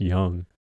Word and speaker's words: Jung: So the guy's name is Josh, and his last name is J-U-N-G. – Yoonji. I don't know, dude. I'm bad with names. – Apparently, it's pronounced Young.